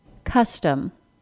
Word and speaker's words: custom: Custom. Fungus. Onion. Succumb. Conduct. Listen again to the word custom. Custom. The word custom has two syllables, the first one stressed and the second one reduced.